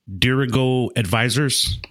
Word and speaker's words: Dirigo Advisors: But is that the Dirigo Advisors? – Yes.